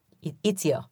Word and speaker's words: Ezio: In Los Angeles, around this time, how do you say his name? Ezio? – Ezio.